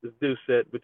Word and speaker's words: Dorsett: It's French, man. It gets butchered up all the time, so I'm used to it. – All right. – It's Dorsett, but you nailed it.